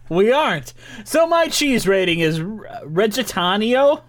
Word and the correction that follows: Reggianito: Oh, no. Reggianito, which is... – you can't even pronounce it. – It's Reggianito, which is made from unpastured and unpastured cow's milk, and it is from Argentina.